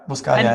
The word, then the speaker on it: Buscaglia: Ich weiß nicht, kennst du Leo Buscaglia? Ich weiß nicht, ob ich ihn richtig ausspreche. – Ja, Buscaglia, ja, absolut.